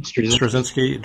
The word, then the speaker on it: Straczynski: Straczynski.